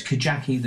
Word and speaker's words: Kajaki: No, it's a hard J, so it's Kajaki, the true story.